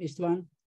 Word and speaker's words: Istvan: Is that the way to say your last name, Istvan? – Yes, perfect.